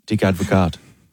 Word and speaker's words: Dick Advocaat: Dick Advocaat. Is how you'd pronounce that. Yeah, come on. – Isn't that Dirk? – Nah, it's Dick. – No, it's Dick. – Dick is a... – Well, it's Dirk is his, like, legal name on the birth certificate, and then he's chosen the nickname Dick.